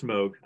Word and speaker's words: Moog: In the keyboard world, do you have a Moog perchance? – I was going, first of all, it is pronounced Moog. I don't mean to be that guy, but it's Wongos.